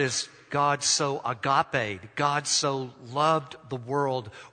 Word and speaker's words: agape: We've taken that Greek word and pronounced it out just as it's pronounced in the Greek language, and we've made it an English word, agape. We say it just like that.